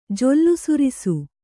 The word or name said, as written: ಜೊಲ್ಲುಸುರಿಸು